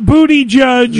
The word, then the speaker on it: Buttigieg: Excuse me, it's pronounced Booty Judge.